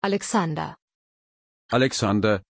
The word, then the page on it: Alexander: German